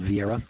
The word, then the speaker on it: Vieira: It'll probably be about $20, and it's written by Edwin Vieira. That's spelled V-I-E-I-R-A.